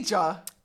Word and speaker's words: Khadija: You Khadija. – Three syllables. – Bro. – Khadija. It's really not that difficult.